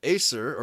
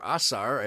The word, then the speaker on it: Ashur: So we can trace the migration of our people through the changes in the language as they migrated from Assyria, Acer, or Assar, as Michael Swede pronounced it. – Ashur. – Okay. Yeah, Ashur, Assyria. Okay.